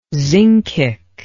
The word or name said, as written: zincic